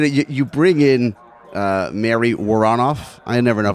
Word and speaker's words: Woronoff: I would run it together. Woronoff. – Woronoff. – I've kind of condensed that a little bit. – I like Woronoff.